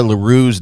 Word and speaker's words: LaRue: James David Rogers of Heath, Ohio was sentenced to 40 months in prison after threatening to rape and kill Eva LaRue's daughter. Did I say that right? – Yeah, I would say LaRue. Yep. Eva LaRue.